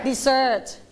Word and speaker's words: dessert: stress the second syllable d dessert